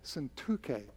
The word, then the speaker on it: Syntyche: And a lot of people say Syntyche or Syntyche. Syntyche, an accent in the middle, that middle syllable.